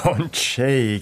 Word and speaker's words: Ondřej: Aber das muss man, glaube ich, tschechisch aussprechen. Hilf mir kurz. – Ondřej Hübel, würde ich sagen, aber ich bin auch kein Experte. – Ondřej, genau.